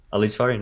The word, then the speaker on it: Ali Jafarian: Ali Jafarian, yep.